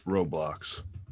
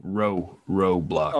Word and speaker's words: Roblox: And am I not pronouncing that right still? – I believe it's Roblox. – Road with a D? – No, Roblox. – Oh, Roblox.